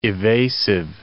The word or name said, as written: evasive